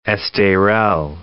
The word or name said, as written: Esterel